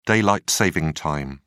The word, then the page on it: daylight-saving time: British English